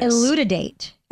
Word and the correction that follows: elucidate: elucidate. Are we pronouncing these right? Yes, elucidate. elucidate.